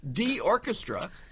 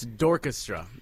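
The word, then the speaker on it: D'Orchestra: In fact, your new band, D Orchestra. – It's Dorchestra. It's spelled D apostrophe orchestra, but it's pronounced Dorchestra.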